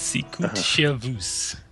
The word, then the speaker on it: Sicut Servus: What's the final thoughts on Sicket Service? Did we find? – It's pronounced Sicut Service.